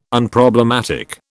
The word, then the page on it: Unproblematic: British English